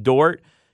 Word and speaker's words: Dort: They swept the season series, and they've got my boy named Dort, I believe is my pronunciation. – Lou Dort, yes.